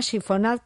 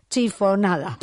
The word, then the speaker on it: chiffonade: Se llama chiffonade porque proviene de la voz francesa chiffonner, que significa arrugar. En castellano, ¿sabes lo que decimos? Chiffonada. Porque nos suena mucho más cómodo de pronunciar.